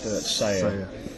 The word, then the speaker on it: Sella: It's actually spelled S-E-L-L-A, but it's pronounced Seya.